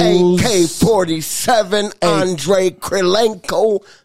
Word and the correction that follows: Kirilenko: How do you say his name again? – Kirilenko. Kirilenko, AK-47, Utah Jazz, man.